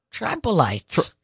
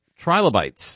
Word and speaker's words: trilobites: Interesting facts about tribolites. – Trilobites.